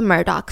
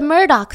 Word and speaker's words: Murdoch: The running dark joke was that this is the, it's spelt like Murdo, right? That's kind of how you want to pronounce it. So everyone's like, oh my God, the murder family. But it's pronounced the Murdoch family.